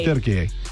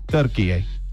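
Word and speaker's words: Türkiye: If you want to be more authentic, you roll your R a little bit. – Turkey. – And it's like a small yay. Turkey.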